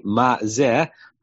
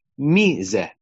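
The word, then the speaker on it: ma zeh (מה זה): It's pronounced traditionally ma zeh, but it looks like mi zeh, right?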